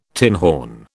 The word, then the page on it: tinhorn: British English